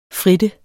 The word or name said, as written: frittende